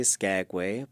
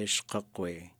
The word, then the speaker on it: Skagway: So some people would say Skagway, but you could also say Shkakwe.